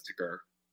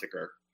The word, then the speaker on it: Tker: You know, I don't think there's really a wrong way to say it, but lately I've been pronouncing it as ticker. It started as T-K-E-R, but now it's ticker.